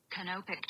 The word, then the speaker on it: canopic: So I'm pretty sure it's Kunapik, but we'll pronounce it just in case. Just to get it right. – Canopic jar. – Oh, Canopic. – Canopic.